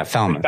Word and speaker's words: Falmouth: You did a 7-mile run in Falmouth. How do you pronounce it? Yeah, Falmouth.